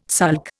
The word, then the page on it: sulk: Indian